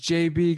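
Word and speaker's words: Jbe: Why would you pronounce that? I saw it. I was like, okay, I'm not going to be able to do this. – Jibby Kruger. So I looked into him. His name's like James Barry Kruger. – What? – Yeah. And it's just pronounced J.B. Kruger.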